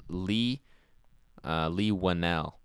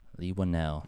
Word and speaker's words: Whannell: It's Lee Whannell. – Lee Whannell.